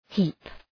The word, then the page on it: heap: hi:p